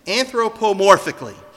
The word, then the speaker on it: anthropomorphically: Anthropomorphically, which means it's from the word anthros, which is man.